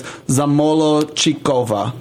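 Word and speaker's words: Zamolodchikova: But the way that I learned it is Zamolochikova. The D is silent.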